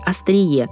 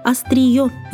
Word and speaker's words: остриё: Из-за того, что мы привыкли к устойчивому сочетанию на острие ножа, мы думаем, что в именительном падеже это слово будет «острие». Но это не так. Верное написание и произношение этого слова – «остриё».